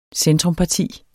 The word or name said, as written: centrumparti